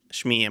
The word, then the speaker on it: Shmi: So, when Qui-Gon is talking to Anakin's mom, I don't know how you say her name, Shmi or Shmi? – Shmi, I'm pretty sure. – Shmi. It just reminds me of Smee from Peter Pan now.